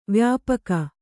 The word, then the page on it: ವ್ಯಾಪಕ: vyāpaka